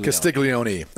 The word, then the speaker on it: Castiglione: Is it Castiglione or Castiglione? – Castiglione. I believe when you're Italian, you pronounce the E.